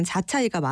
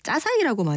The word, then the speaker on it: 자차이: 이게 외래어 표기법상으로는 자차이가 맞아요. 근데 보통 중국 음식점에 가면 우리 짜사이라고 많이 부르죠. 중국 본토의 발음과 한국에 정착한 화교들의 방언 발음, 거기에 한국인들이 귀로 들은 소리가 절묘하게 섞이면서 짜사이로 정착이 된 거라고 합니다. 근데 원래 표기법상 자차이가 맞는 거죠.